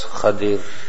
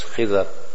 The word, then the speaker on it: Khidr: Musa alayhi salatu wassalam meets the slave from the slaves of Allah tabaraka wa ta'ala, whose name was Khadir, which we generally pronounce as Khidr.